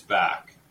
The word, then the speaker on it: Bacque: Well, you know, it is spelled B-A-C-Q-U-E. So, I mean, I've heard just about every, interpretation of this under the sun, but I've been told since I was a boy, it's back, like front to back.